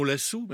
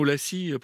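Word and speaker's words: Lassy: un duo du saxophoniste Timo Lassu, même si ça s'écrit avec un Y à la fin, vous l'avez peut-être entendu prononcer Timo Lassi précédemment, avec Teppo Mekkunen à la batterie.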